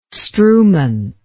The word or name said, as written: strewment